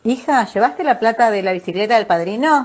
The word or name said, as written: bicicleta